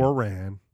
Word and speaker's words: Quran: Quran, how do you say it? No, I'm kidding. – Quran. – Thank you.